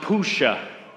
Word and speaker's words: puxa: It's a Portuguese word, and it's pronounced puxa, puxa.